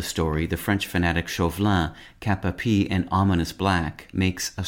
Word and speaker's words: cap-a-pie: Knights thus outfitted were said to be armed kap-uh-PEE. The term kap-uh-PEE,